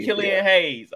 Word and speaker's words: Killian Hayes: Killian Hayes.